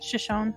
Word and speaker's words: Shoshone: Shoshone.